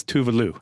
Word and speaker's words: Tuvalu: The, the correct pronunciation name is Tuvalu.